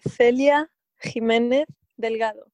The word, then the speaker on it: Celia Jiménez Delgado: It would be Celia Jimenez Delgado.